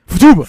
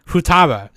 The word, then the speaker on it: Futaba: Futuba. – Futuba. Yeah. Futaba. Yeah. You gotta shoot Futaba. I knew I was saying that wrong.